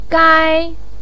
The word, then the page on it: gai1: pinyin